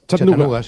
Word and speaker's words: Chattanooga: ¿Se pronuncia así en Chattanooga? – Chattanooga, sí.